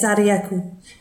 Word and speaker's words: Sarayaku: This is with someone who I think is now the president of the Sarayaku people. Have I said that right? – Yes, Sarayaku, which means river of corn.